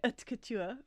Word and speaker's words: haute couture: I was doing so well with all my pronunciation and then I stuff up haute couture. He was asked where haute couture fit within French culture.